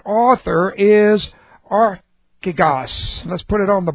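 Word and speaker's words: Archigos (ἀρχηγός): That's A-R-C-H, long E-G-O-S. Okay. Archigos. It's made up, it's a compound word. It's made up of arche, A-R-C-H, long E, arche, which has different concepts. It means first. It means first. It means the beginning. Plus, ago, A-G, long O, and that means to go ahead of, to lead. So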